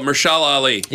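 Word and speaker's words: Mahershala Ali: Meshumbapalatin Ali. – Yep. – Oh, Mershala Ali.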